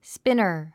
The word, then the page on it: spinner: spínər; スピナァ